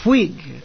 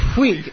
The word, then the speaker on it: Whig: You were a Whig, Lord Melbourne. – Yes, I must correct your pronunciation, Headley. Whig. – Oh, Whig. W-H-I-G.